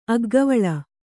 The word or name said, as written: ಅಗ್ಗವಳಿ